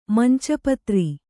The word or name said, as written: ಮಂಚಿಪತ್ರೆ